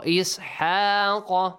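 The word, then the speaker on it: Ishaq (إسحاق): Ishaq qa qa look qaf here it's a heavy one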